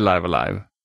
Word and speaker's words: Live A Live: Så att det heter Live Alive. Det är liksom det korrekta uttalet, men det var liksom, jag har ju hört så mycket olika.